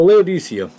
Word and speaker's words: Laodicea: So Laodicea, that's how I pronounce it. Laodicea, Laodicea, you know, I've actually heard different pronouncing, which is funny.